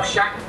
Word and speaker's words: Schacht: Hjalmar Schacht. Is the T pronounced or not? – I think so. Schacht.